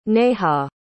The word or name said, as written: neha